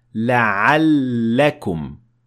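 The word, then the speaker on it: la'allakum (لعلكم): Like in these examples, here in this word, the lam has shadda, which means that you're going to pronounce the lam two times, and you would say la'allakum.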